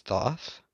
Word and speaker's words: Thoth: So, such as Aradia, Nuit, Urania, Elnil, Merawrim. I believe I mispronounced that, and I believe that's Thoth.